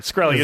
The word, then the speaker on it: Shkreli: December 9th, pharmaceutical executive Martin Shkreli, I think is how it's pronounced. – Shkreli, I think. – Shkreli, thank you. You're right, Shkreli.